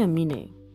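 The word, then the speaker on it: Aminé: How do you say that guy's name? I say Amine.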